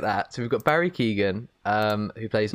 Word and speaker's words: Keoghan: I think it's Keoghan. I don't know how it's. I think it's Keoghan because everyone pronounces it. Some people say like Cohen. And it's like, well, it's not Cohen.